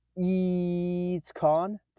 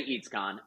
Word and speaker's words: EEEEEATSCON: I'm going to pronounce it EEEEATSCON. Did I do that correctly? – You did it great. Honestly, you nailed it. You can also say EEEEATSCON, and people would be fine too.